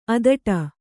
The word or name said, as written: ಅಧಟು